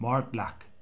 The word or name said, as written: Mortlach